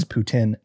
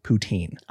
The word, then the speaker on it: Putin: It is Putin, not poutine.